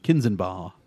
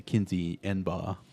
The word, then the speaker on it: Kinzenbaugh: So today we're talking Kinze planters, and it's going to make me want to mispronounce his name, because it's Kinze Welding, first opened in 1965 by John Kinzenbaugh, but it wants me to say Kinze Enbaugh, just because it's Kinze, but as a small welding and repair shop in Ladora, Iowa.